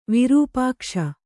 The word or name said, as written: ವಿರುಪಾಕ್ಷ